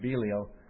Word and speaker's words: Belial: Sons of Belial or sons of Belial, depending on the pronunciation, B-E-L-I-A-L, is a common expression for worthless people.